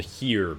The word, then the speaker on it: Xeer: I think this is called HEER, by the way. – Okay, HEER, not ZEER. It's because it's spelled X-E-E-R.